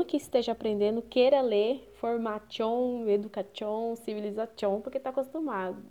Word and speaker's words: formation: Mas no francês, todas as palavras que possuem o final em T-I-O-N são pronunciadas como se fossem C. Por exemplo, formation, que a gente fala em português, se lê formation.